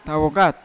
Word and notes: tawokat ko: Yawa

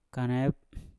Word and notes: kanep: Mpur

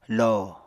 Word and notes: lo: Apatani

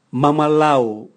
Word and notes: mwamwalau: Raga

